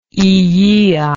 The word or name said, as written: illia